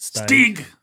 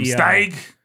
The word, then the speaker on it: Steig: it's a German name, and a German would pronounce it Steig. – Steig? – Steig. – William Steig? – Steig.